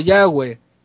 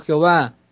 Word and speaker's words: Yahweh: Sí, mi hermano, no es pecado si tú pronuncias como Yahweh. Tampoco es pecado aquel que le pronuncia como Jehová. Tampoco es pecado aquel que lo pronuncia como Yahvé.